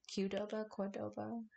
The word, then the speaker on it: Qdoba: I'm more of a Qdoba guy. – Oh, I like Qdoba. Qdoba? How do you say that? – Qdoba.